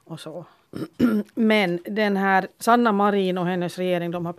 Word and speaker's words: Sanna Marin: Ja, jag har fått lära mig att vi ska uttala det som hon själv säger det. Mm. Och då blir det Sanna Marin.